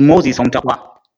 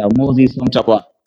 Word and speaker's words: Moses Homtapwa: My name I use on Facebook is Moses Homtapwa. The Homtapwa is in language. May I spell it? Homtapwa. Yes, Moses Homtapwa.